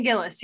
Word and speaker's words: Gillis: Shane Gillis, or Gillis, I don't know if that's how you pronounce it. – Right, Shane Gillis, yeah.